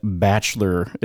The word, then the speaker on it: bachelor: Also, you was kind of shocked that Bachelor is three syllables. Apparently, you're supposed to pronounce it Bachelor. I just say Bachelor.